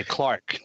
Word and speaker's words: clerk: the word clerk because they don't pronounce it that way. They say Clark.